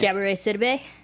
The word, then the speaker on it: Gabourey Sidibe: Gabourey Sidibe.